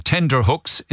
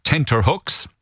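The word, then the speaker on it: tenterhooks: For years, I was saying tender hooks instead of tenter hooks.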